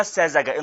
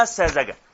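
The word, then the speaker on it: الساذجة: اسمها الساذجه انطقي عدل اسمها الساذجه شوفي يا استاذه سماح شوفي ما بتعرفيش تنطقيها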